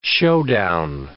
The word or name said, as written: showdown